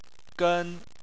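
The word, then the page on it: gen: tone 1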